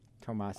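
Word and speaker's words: Tomáš: Panthers get future considerations for Thomas Jurko. – Tomas. – I got it right. – Tomas Jurko. – No, damn it. – Jurko. – Well, it's Jurko? Still Tomas, not Thomas.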